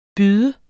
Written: byde

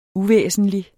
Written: uvæsentlig

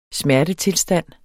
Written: smertetilstand

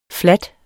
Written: flade

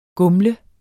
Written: gumle

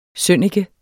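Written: SØNNIKE

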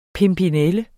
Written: pimpinelle

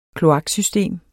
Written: kloaksystem

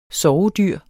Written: sovedyr

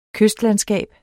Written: kystlandskab

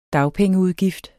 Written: dagpengeudgift

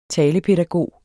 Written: talepædagog

